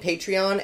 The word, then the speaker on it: Patreon: I'm saying Patreon as the official correct version because I saw Amanda Palmer pronounce it that way, and she's kind of OG Patreon person, it would seem.